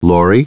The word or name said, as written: Laurie